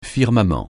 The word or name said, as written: firmament